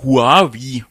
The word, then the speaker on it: Huawei: Ja, fast schon ein Tablet ist auch eines der Smartphones, die Huawei oder Huawei, wie es offiziell ausgesprochen wird, der chinesische Hersteller, vorgestellt hat, das Huawei Ascend Mate.